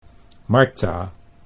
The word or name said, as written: Martha